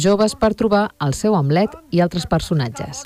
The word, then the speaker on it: Hamlet: Roger Torns signa aquesta adaptació lliure en què Horacis, un director d'escena narrador, el nom del turmentat príncep danès es pronuncia Hamlet i lluita contra les coses podrides.